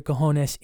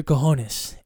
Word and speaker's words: cojones: The J is silent. It's like a yone, a honest, all right? Cajones, all right? C-A-J-O-N-E-S, Major Cojones.